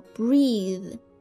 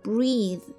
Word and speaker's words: breathe: 好，那在这个词词尾加上 e 就成了一个动词，读音呢也发生了改变，叫做 breathe， breathe， breathe，也是呼吸的意思。